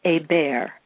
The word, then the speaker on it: Hebert: And my name, my pronunciation of my name is the French pronunciation. It's Michelle Hebert, H-E-B-E-R-T.